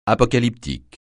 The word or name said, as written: apocalyptique